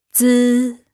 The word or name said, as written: zi